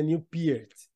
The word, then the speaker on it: Peart: é, se pronuncia Newport.